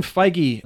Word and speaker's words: Feige: Kevin Feige, the guy who's been in charge of the... – Is that seriously how you pronounce his name? – I've always... Yeah, because it's Paul Feig and Kevin Feige.